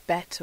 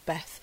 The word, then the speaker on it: Bet: I'm getting the Hebrew letter B because it's pronounced Bet or Beth.